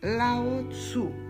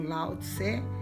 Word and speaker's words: Lao Tzu: Mas eu agora me refiro a Lao Tzu, para tentar falar um pouco mais parecido com aquilo que é a pronúncia original, mas ele ficou conhecido como Lao Tse que é o pensador original do taoísmo, né?